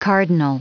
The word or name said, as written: cardinal